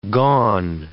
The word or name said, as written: gone